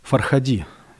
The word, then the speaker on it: Фархади: Да, мы тут даже специально выясняли, как делается ударение фамилии режиссера. В общем, Асгар Фархади, его нам дипломированные специалисты сказали, что ударение всегда здесь ставится на последний слог.